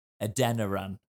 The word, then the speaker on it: Adeniran: And obviously, Adeniran as well. That is how you say his name, innit? – Yep.